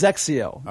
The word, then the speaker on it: XXIO: Yes, yeah, pronounced Zexio. Okay. The X's are Z's in the Japanese.